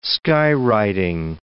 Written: skywriting

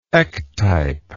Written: ectype

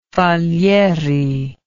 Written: falieri

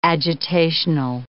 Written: agitational

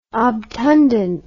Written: obtundent

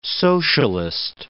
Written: socialist

